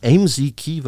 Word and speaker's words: Amzkey: Aber lass uns mal kurz über AMZKey, wird das mhm. – ausgesprochen, mhm. – A-M-Z-K-E-Y geschrieben.